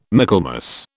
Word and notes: Michaelmas: British English

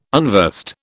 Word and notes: unversed: British English

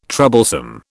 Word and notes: troublesome: British English

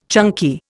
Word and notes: chunky: Indian

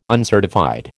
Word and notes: uncertified: American English

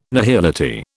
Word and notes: nihility: British English

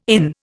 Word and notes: inn: Indian

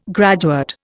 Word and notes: graduate: Indian

